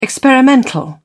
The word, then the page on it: experimental: British English